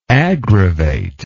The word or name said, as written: aggravator